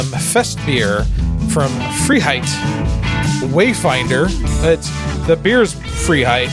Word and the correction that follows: Freiheit: The brewery is Wayfinder. – You butchered it. It's Freiheit.